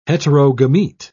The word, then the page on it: heterogamete: British English